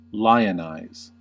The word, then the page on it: lionize: American English